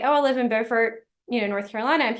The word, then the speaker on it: Beaufort: But first, we talk about where she lives, Beaufort, North Carolina, which looks the same, but is not pronounced the same, as Beaufort, South Carolina.